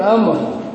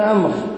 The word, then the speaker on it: عمرو: لا، عند ابن عمرو. حدثنا معاوية بن عمرو. لحظة، لا، التمييز سهل بين عمر وعمر. عمر بفتح العين بعدها واو تكتب ولا تنطق، وعمر بضم العين لا يكتب بعدها شيء.